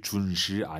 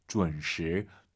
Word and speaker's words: 准时: 준시, 준시 아니에요. UN 사이에 어 들어가야 돼요. 준, 준시, 준시. 쉽지 않습니다. UN 발음을 어려워하시면은요, 결혼하다를 떠올리세요. 결혼하다, 지혼 아니죠?